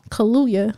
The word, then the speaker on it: Kaluuya: Like, I didn't know Daniel Kaluuya, how you say his name? – Kaluuya. There's two U's.